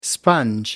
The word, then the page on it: sponge: American English